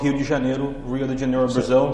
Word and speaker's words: Rio de Janeiro: Rio de Janeiro. – Rio, because the R is with the H.